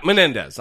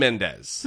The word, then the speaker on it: Menendez: Yeah, Menendez. I said his name wrong, like a like a dumb shit. So anyway, did you? – Yeah, I think I called him Mendez.